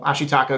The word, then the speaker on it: Ashitaka: But, you know, as it relates to that scene, you want to understand how intense of an emotion that, you know, Ashitaka is going. Did I say that right? – Yeah. – That Ashitaka is going through in the context of the scene.